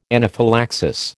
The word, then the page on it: anaphylaxis: American English